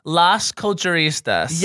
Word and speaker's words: Las Culturistas: So, no, we have the Lacanistas here. – Girl. – Oh. Got it. – Is that how you say it? – Here's how you say it. Las Culturistas. – Yes, Las Culturistas.